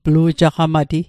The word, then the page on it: plu'jaqamati: Mi'gmaq